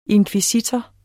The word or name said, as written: inkvisitor